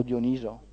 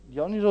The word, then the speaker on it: Dioniso: O Dioniso. Dioniso o Dioniso? – Dipende dalle pronunce, comunque Dioniso può andare bene.